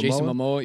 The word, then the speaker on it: Momoa: How do you say his last name? – Momoa? Jason Momoa, yeah.